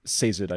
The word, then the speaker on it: Sazed: I also hear Sazed sometimes, but I believe, Caleb, like you mentioned, because of the nickname Saze, just a couple lines later, that is... Sazed, I believe, is the official pronunciation.